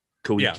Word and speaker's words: Koike: Koike, is that how you said it? Koike? – Yeah, that's how it's said.